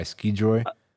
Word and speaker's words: Scijoy: Yeah, okay, it's Skijoy. S-C-I-J-O-Y. Skijoy.